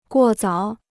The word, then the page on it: 过早: guò zǎo